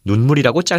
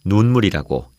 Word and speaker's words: 눈물: 네, 얼굴에 있는 눈에서 나오는 물인 경우에는 눈물이라고 짧게 발음하지만 하늘에서 내리는 눈이 녹아서 된 물인 경우에는 눈물이라고 길게 발음한다는 차이가 있습니다.